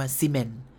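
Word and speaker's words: semen: S E M E N อ่านว่าซีเมน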